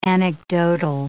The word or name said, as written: anecdotal